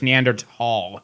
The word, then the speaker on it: Neanderthal: It's not a TH anymore. Yeah. You pronounce the T and the H, so it's Neanderthal.